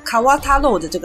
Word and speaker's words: 河太郎: 水獭的日文念法跟河童其实有点像，那个时候河童还是比较流行，就是河太郎叫卡瓦塔洛的这个念法。